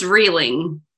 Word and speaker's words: Rieling: It's Rieling.